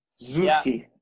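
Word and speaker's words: Suchy: So I noticed you've got one of my favorite little independents can you pronounce it for everyone? The Suchi and Son or Zone? Suchi and Zone? – Karl Suchi and Söhne. – Zuki. Yeah. Okay. Zuki, very cool.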